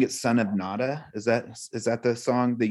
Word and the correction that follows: Nanda: What is it? Say it right. I definitely said it wrong. What was it? – It's Nanda, Nanda is Krishna's father, son of Nanda.